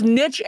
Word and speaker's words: niche: Okay, so both niche and niche are legit then.